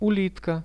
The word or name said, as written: ulitka